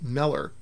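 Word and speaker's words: Müller: In my research, George's name, with the German umlaut above the U, actually causes the name to be pronounced Meller.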